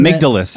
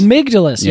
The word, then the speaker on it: amygdalus: Isn't amygdalus. – That, amygdalus. Yeah. That's the way I would pronounce it.